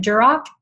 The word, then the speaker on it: Duroc: Yeah, and that directly leads us back on track to our online questions where someone asked, you mentioned Duroc, did I say it correctly? – Yeah, yeah.